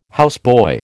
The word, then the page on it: houseboy: American English